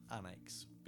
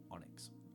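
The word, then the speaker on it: Anax: Also pronounced Annex because it's A-N-A-X. Mm. It's actually pronounced Onyx.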